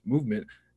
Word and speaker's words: MVMT: With fair prices, unexpected colors, and clean original designs, MVMT, pronounced Movement, grew into one of the fastest growing watch brands, shipping to over 160 companies across the globe.